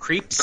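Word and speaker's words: Krieps: Vicky, is it Creeps? Creeps? How do you say her name is Alma? – I would think Creeps. – Creeps, yeah.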